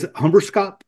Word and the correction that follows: hemberedskap: Or did I say it wrong? Correct. Yeah. Say it correctly. How about that? – Hemberedskap. – Hemberedskap.